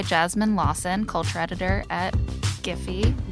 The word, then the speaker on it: Giphy: I'm like, is it Jiffy or Giphy? – It's Giphy. – Okay. – We say hard correct. – G for hard G's.